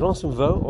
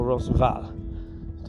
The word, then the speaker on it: Roncesvalles: It's pronounced two ways Roncesvalles or Ransomval, depending if you want to do French or Spanish.